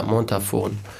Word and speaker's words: Montafon: Ich war zuerst in Montafon, das ist in Österreich, im Brandnertal. – Wie schreibt man Montafon? – So wie es gesprochen wird. Mit F. – Ja, Montafon.